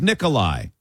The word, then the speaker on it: Nikolai: My given name is Nikolai. It's pronounced the same way as Nicholas, but with a long I at the end.